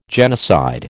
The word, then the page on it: genocide: American English